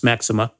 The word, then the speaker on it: Maximin: But in any event, the tradition says that these three, along with a man called Maxima, it's spelled Maximin, but in the south of France, it's pronounced Maxima.